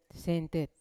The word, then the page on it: sentet: Yawa